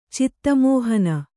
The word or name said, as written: ಚಿತ್ತಮೋಹನ